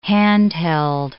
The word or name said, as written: handheld